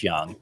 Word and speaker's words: Jung: In fact, that was there before the power showed up for Josh Young, which is spelled J-U-N-G, by the way. – Yes, yes, like the German philosopher.